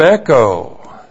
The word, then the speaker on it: echo: Verb echo. E-C-H long O. Echo.